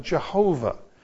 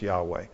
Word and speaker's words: Yahweh: And so they looked at the letters and so on and came up with Jehovah, which for hundreds of years was how Christians pronounced the name. But several generations ago, scholars took that apart and said, it's probably better pronounced Yahweh.